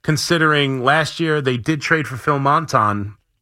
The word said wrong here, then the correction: Maton: at, am I saying that right? Why did that come out wrong? – Maton. – I knew as soon as I said it, that didn't sound right. Maton last year on July 10th.